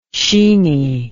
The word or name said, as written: sheeney